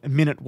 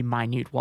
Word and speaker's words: minute: There's often been a lot of confusion about that because minute and minute, it's spelt the same. So a lot of people just say minute waltz, but apparently it is actually supposed to be minute waltz.